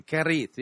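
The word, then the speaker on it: Cherith: And by the way, that's not a bad pronunciation, Keris. Yeah, you got to get, you got to roll your R on that.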